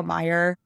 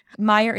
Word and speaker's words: Meijer: For those who are from Michigan, Meyer is spelled like the chain of stores in Michigan. Fun little thing that only Michigan people will understand. Meyer is spelled M-E-I-J-E-R.